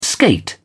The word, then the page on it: skate: British English